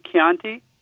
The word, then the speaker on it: Chianti: P-O-R-C-H-E-T-T-A. That C-H is really important. Most of us say bruschetta wrong. It's actually bruschetta. So anytime you see a C-H in the Italian language, pronounce it like a K, because there is no K in the Italian language. So C-H replaces the K. So, for instance, that bottle of wine that comes from Tuscany, Chianti, it's Chianti. – C-H, right? And Pinocchio. – Right. – And Orecchiette. It's all that CH, and that will change everybody's pronunciation about every Italian word. Once you see CH, say K, and you're golden.